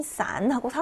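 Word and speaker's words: 싼하고 (伞): 네, 우산은 싼하고 삼성으로 읽죠. 흩어지다, 헤어지다 할때 싼이라고 사성으로 읽었어요. 이것과 성조만 다르고 발음은 같잖아요.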